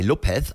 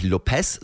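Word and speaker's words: López: Daniel Lopez, sag mir. Und es ist immer wichtig, wenn ihr Daniel Lopez sagt, dass ihr bei Lopez, also am Ende, das spricht man nicht Lopez, sondern Lopez.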